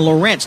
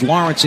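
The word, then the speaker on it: Lorentz: By the way, in case anybody hears a couple of different pronunciations, it's either Stephen Lawrence or Stephen Lorenz, depending on who you talk to. Stephen always told us it was Lawrence in his side of the family, so that's how I'm going to say it.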